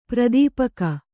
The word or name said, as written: ಪರಿತಾಪಿಕಿ